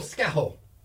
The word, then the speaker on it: Scahill: That was Fergal Skaho. His last name is spelled S-C-A-H-I-L-L. It's a Carrie name.